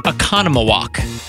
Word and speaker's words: Oconomowoc: Okay, so this one is pronounced Oconomowoc.